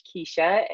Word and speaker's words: Keisha: So you guys can find me, follow me on Instagram at Coach Keisha. And my name is spelled K-E-I-S-H-A.